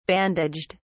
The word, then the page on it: bandaged: British English